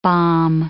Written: bomb

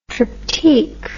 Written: triptyque

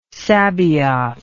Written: sabaoth

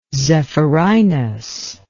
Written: zephyrinus

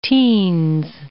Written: teens

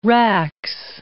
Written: rax

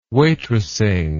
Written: waitressing

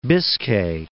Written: Biscay, Bay of

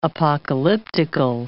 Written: apocalyptical

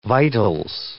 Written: vitals

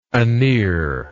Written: anear

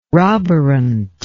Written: roborant

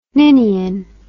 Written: ninian

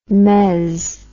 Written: mezz

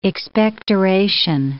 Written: expectoration

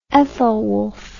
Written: Ethelwulf

